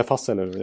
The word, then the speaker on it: Fasel: Fasell! Ja, ja. Eller Fassel, ja, uttalet är ju högst tveksamt. – Fasel heter han!